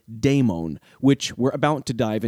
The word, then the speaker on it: daimon: In order to distinguish demons from daemons, in modern discussions, the latter is generally transliterated into English as D-A-I-M-O-N, and it often maintains a unique pronunciation.